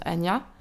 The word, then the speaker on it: Anya: Et alors, moi, je le prononce Anja. En soi, c'est un prénom russe qui ça se prononce Anya.